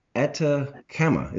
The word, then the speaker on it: Atacama: Atacama.